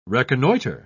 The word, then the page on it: reconnoiter: British English